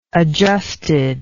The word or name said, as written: adjusted